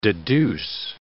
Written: deduce

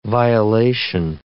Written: violation

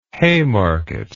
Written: haymarket

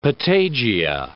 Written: patagia

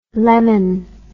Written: Lemmon

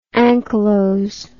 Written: anchylotic

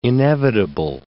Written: inevitable

